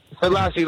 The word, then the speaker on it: Selassie: Selassie, like Haley Selassie.